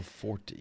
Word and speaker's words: Forte: How about Will Fort? – Will Fort. – Maybe it's pronounced Forte. I'm going to be honest. – I think it's Will Forte. Yeah, there is an E on the end.